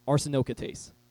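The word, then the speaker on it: arsenokoitai: Say that with me, arsenokatoa. Oh no, pronounce it, arsenokatase.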